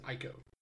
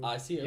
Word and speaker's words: Ico: Excuse me, you might pronounce it Ico. – Echo? – Yeah. – ECCO. – ICO. – Yeah. No, I'm talking about Ico.